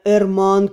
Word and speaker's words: Эрман: Мое имя Эрман Кейлбирд». – Назвался он, выделяя ударением второй слог имени.